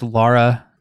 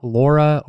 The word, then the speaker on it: Lara: My gaming related hill is it's pronounced Lara, not Laura or Lara.